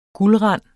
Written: guldrand

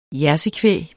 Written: jersey

